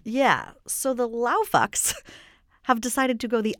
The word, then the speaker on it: LAOFX: I believe it's pronounced LAOFX. – LAOFX. I like that.